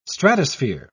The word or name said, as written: stratosphere